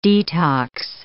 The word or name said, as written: detox